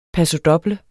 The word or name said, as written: pasodoble